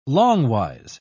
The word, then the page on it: longwise: British English